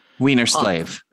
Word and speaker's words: Wienerslav: Also, like, you're Jeffrey Wienerslav. Is that how you say it? – Wienerslave. – Wienerslave.